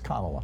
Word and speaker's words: Kamala: I've heard mispronounce it on Fox News, and Trump mispronounces it as a way of slurring her. It's Kamala.